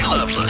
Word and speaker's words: Lovelace: FM studio with Patti Loveless. – I married a guy from North Carolina. His name is spelled Lovelace, but they pronounced it Loveless.